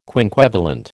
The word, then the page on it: quinquevalent: American English